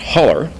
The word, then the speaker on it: hollow: Deep within the Appalachian Mountains, however, the word is pronounced holler and is used to describe the seam where two mountains join.